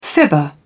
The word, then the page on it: fibber: British English